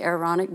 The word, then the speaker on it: Aaronic: Aaronic, how do you say, Aaronic blessing, yeah, the Aaronic blessing.